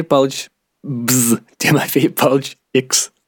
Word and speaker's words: Палыч: А вот числе ударение на первом слоге, а все остальное глотается. Тимофей Палыч.